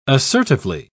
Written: assertively

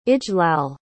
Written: ijlal

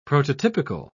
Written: prototypical

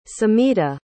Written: samhita

